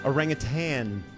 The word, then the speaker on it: orangutan: Orangutan. – I'm sorry. – Yeah. – Excuse me? – Yeah, do not mispronounce it. – It's tan, not tang. My bad. – There's no G.